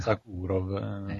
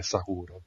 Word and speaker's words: Sokurov: Sakurov. Come si pronuncia, giusto? – Sakurov, d'ora in poi sappiatelo, non mette le bocche, lo pronuncia lui così, quindi è Sakurov.